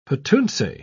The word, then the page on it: petuntse: British English